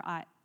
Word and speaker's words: Ai: And then they went on to their next battle, which is to conquer the city of Ai. It's pronounced Ai, I believe.